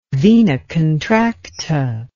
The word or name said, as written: vena contracta